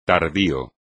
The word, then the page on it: tardío: espagnol